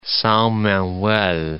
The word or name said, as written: Sao Manuel